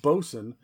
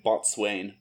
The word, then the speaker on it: bosun: It was captain, first mate, bosun, quartermaster, surgeon, and cook. – Yeah, I like it. I like it that you wrote bosun phonetically so that nobody ran the risk of saying botswain, which is good.